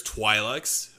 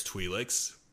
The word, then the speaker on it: Twi'lek: While the Twi'lek, there's like multiple ways you could pronounce Twi'leks. There's Twi'leks and there's Twi'leks. But from what I've heard, both of them are correct, basically.